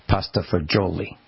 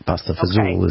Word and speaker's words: pasta fagioli: Olive Garden pronounces it pasta fagioli. Pasta fazool is how the Finelli say it.